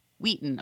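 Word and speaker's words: Weighton: I also wanted to give a shout out to my little childhood hometown market of Market Wheaton, pronounced Wheaton. Okay, so I mispronounced that.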